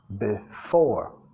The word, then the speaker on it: before: Yesterday and, vinculando, yesterday and days, sonido Z, before. Enfatizando la segunda sílaba.